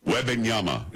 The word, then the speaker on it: Wembanyama: Victor Wembanyama. Wembanyama is how you pronounce it.